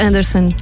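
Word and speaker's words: Andresen: É assim que se escreve, mas diz-se Anderson.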